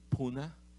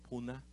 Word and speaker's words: Pune: You know, when we were in India, we were in this city, Pune, or Pune. They call it Pune or Pune. Both, both names, they use it.